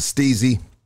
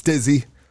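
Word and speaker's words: STIIIZY: I want to give a big shout out to Steezy. Everybody keep on telling me I'm saying the name wrong. They say it's Stizzy, but I like Steezy, baby, you know? So I'm going to say Steezy.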